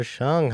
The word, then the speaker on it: Hsiung: In the studio today, we got Jennifer Hsuing. How are you? – I am very well and even happier that you got my last name pronounced correctly on the first go. – It is a little bit of a mystery with the H-S-Z-T.